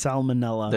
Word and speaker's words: salmonella: Like, yes, you could theoretically get salmonella because it is technically raw Get what? – Did I add a syllable? – You did. – It's salmonella.